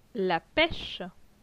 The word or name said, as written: La peche